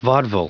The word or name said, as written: vaudeville